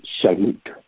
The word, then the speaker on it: shalom: In the Hebrew, the word whole or wholeness is pronounced shalom, shalom, which means wholeness.